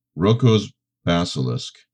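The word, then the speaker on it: Roko's Basilisk: Rocco's Basilisk. Am I saying that right, Pete? The most terrifying thought experiment of all time. – Yeah. Yes.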